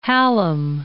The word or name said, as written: Hallam